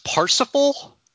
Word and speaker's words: Parsifal: It's pronounced Parsifal, like the mosaic.